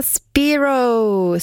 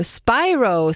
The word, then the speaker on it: Spiro: Sienna Spiro, Spiro säger man förstås.